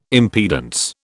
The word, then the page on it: impedance: British English